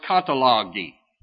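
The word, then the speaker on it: katalogi (καταλλαγή): It is the from the word in the Greek manuscript is pronounced katalogi.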